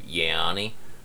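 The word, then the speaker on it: Yianni: He will most likely, assuming he's on his game, he's going to make it to the quarterfinals, and he's going to have Yanni. Am I saying that right? From Cornell? – Yeah.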